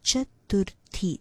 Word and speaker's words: chaturthi: I've noticed a lot of times when people want to say this word, they say chaturthi. That is not right. It is chaturthi. There's no the sound anywhere in this particular word. It is chaturthi, okay? That is how you pronounce it.